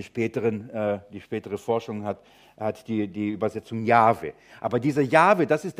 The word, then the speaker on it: Jahwe: Und eine Zeit lang wurde Jahwe wie ausgesprochen? Jehova. Und die spätere Forschung hat eigentlich rausgekriegt, das ist die falsche Leseart.